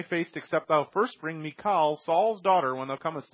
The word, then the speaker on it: Michal: I need Michal, however you want to say her name. Michal is the Hebrew pronunciation.